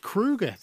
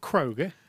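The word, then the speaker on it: Kroeger: It's pronounced Kruger. That was announced this week. Yeah, it's not Kroger, it's Kruger.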